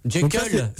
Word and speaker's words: Jekyll: On va balancer la musique Jekyll and Hyde. Je le dis bien? – Ouais. – Ou je le dis pas bien? – Ah oui, c'est Jekyll.